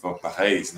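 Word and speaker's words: Van Parijs: E, por acaso, quem liderava esse centro era o Felipe Vampares, que é ou Felipe Vampa Reis, né, como se fala lá em, em Flamengo, né?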